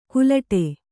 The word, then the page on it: ಕುಲಟೆ: kulaṭe